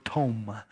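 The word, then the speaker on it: tom (תֹּם): The Hebrew word here in this passage is the word tom, spelled like Tom. It's tom.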